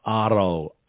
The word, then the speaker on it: Aro: Coming up, a track from Aro, A-R-O is how you pronounce the name, and it's called Mundane.